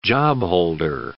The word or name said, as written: jobholder